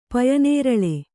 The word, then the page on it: ಪಯನೇರಳೆ: Kannada